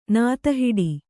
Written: ನಾತಹಿಡಿ

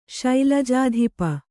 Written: ಶೈಲಜಾಧಿಪ

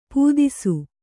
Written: ಪೊದೆಸು

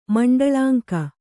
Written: ಮಂಡಳಾಂಕ

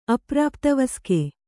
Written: ಅಪ್ರಾಪ್ತವಸ್ಕೆ

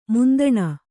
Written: ಮಂತಣ

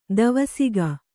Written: ಧೀವಸಿಗ

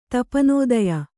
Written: ತಪನೋದಯ

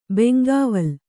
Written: ಬೆಂಗಾವಲ್

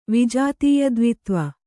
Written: ವಿಜಾತೀಯದ್ವಿತ್ವ

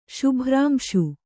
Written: ಶುಭ್ರಾಂಶು

